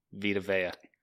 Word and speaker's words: Vita Vea: How am I supposed to pronounce it? – Vita Vea.